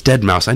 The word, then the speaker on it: Deadmau5: It's Deadmau5. I know it's right. Deadmau5, but it's spelled Deadmau5, like dead, right, as in the word Chairman Mao, and then the number 5.